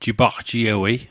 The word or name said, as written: jipagjiewei